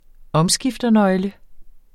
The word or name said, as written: omskifternøgle